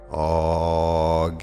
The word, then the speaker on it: AGLA: Then you turn 90 degrees to your right so that you're facing north and you vibrate Agela. Some people pronounce it Agla.